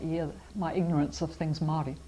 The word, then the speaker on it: Māori: It's Māori. But Māori is the correct pronunciation.